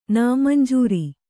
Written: ನಾಮಂಜೂರು

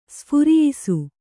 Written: ಸ್ಫುರಿಯಿಸು

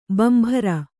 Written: ಬಾಂಬರು

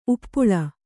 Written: ಉಪ್ಪಲ

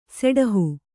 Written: ಸೆಡಹು

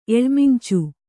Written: ಎಳಮಿಂಚು

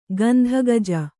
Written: ಗಂಧಗಜ

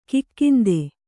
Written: ಕಾಕೇಂದು

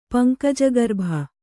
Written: ಪಂಕಜಗರ್ಭ

